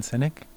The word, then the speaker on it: Sinek: I think I brought him up at the Cal Bike, but Simon Sinek. – It's his actual name. It's spelled S-I-N-E-K.